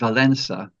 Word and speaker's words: Wałęsa: And we'd been recommended Wałęsa and Wojtyła. But the L in both those names has a bar through it, which means that it's actually pronounced like an English W rather than an English L.